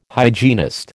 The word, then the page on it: hygienist: American English